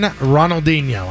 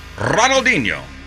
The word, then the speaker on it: Ronaldinho: Brazilian football legend Ronaldinho. I think I'm saying that right? – No, it's Ronaldinho.